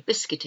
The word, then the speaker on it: biscuit: And I think you all know what a biscuit is. English people like to have our tea and biscuits. So it's spelled B-I-S-C-U, silent U-I-T, biscuit, biscuit.